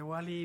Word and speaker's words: Ouali: Deletreanos Wally porque de pronto la gente cree que es Wally como el de la película. – Listo, se escribe O-U-A-L-I.